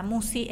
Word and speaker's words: Musci: Así que eh, YouTube es eh, Marisa Musi, el nombre, mi apellido se escribe M-U-S-C-I.